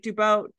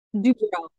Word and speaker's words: Dubrow: Like, I have the frame frozen on Heather Dubrow, like Dubow. How do you say it? Dubrow? Dubrow. – Dubrow, yeah.